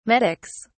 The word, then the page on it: medics: British English